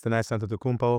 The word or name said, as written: Thanasis Antetokounmpo